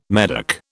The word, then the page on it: Medoc: British English